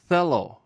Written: qelw

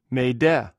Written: mhde

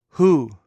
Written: hou

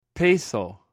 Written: peiqw

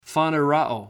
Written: faneroo

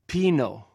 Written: pinw

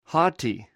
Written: hoti

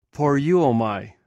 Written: poreuo

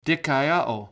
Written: dikaioo

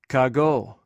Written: kagw

